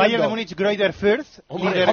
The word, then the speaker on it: Fürth: Ah, muy bien Greuther pronunciado. Fürth. – ¿Se dice así? Ah, el líder con... ¿Se dice así, Paniagua? – Creo que sí. – ¿Tiene nombre? – Deletréame el nombre, el segundo. – F-U-R-T-H. – Fuert. – Como en Cataluña, ¿no? – Para 1-0.